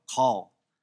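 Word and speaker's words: chol: but it's C-H-O-L, kol.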